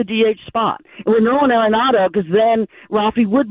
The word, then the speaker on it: Arenado: The one reason I would have preferred Nolan Arenado, is that how his name is pronounced? – Yep.